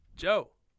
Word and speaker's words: DJO: Joe. – Is that how you'd say that? – I'd say Joe. Like the D Django. – Okay. – The D silent. – Joe.